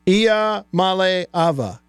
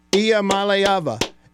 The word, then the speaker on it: Iamaleava: I'm Nico Iamaleava, and the correct way to pronounce my last name is Iamaleava. – Iamaleava. Got it? Repeat after me. Iamaleava.